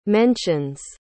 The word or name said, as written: mentions